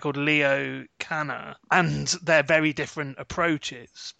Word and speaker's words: Kanner: It looks like Kanner, but it's pronounced Kanner.